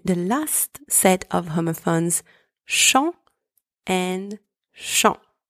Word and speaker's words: champs: The first one, spelled C-H-A-M-P-S, means field. In this word, the letters C and H together are pronounced sh. The letters A and M together are pronounced on, and the final consonants P and S are silent. Second one, chant, spelled C-H-A-N-T, means song, or like the act of singing.